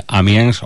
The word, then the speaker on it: Amiens: Totalmente, eso es un punto importante de atracción para el turismo en esta ciudad en el norte de Francia, Amiens, o Amiens, como pronuncia perfectamente Juan Antonio, con ese macarrón y con todo lo que nos ha contado.